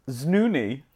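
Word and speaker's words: Znüni: The word is znoony. – Znoony? Oh my God, how do you spell it? – Z-N-U with umlaut, N-I.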